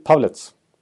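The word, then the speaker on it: Pavelec: det är ju han i och med att eh, eh, eh, hur uttalar man han i Winnipeg då? Pavel, Pavlets. Pavlets.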